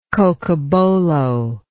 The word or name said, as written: cocobolo